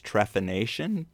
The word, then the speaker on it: trepanation: You know, when we first meet him, he's fascinated by this device called I guess it's pronounced trepanation brace. – Yes.